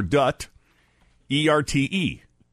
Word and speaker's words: Duterte: D-U-T-E-R-T-E. – D-U-T-E. Duterte. – That's, there's no U after the T. D-U-T, Dut or Dut, E-R-T-E.